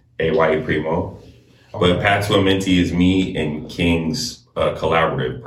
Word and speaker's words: Amenti: Make sure I'm pronouncing this right. Path to Amenti.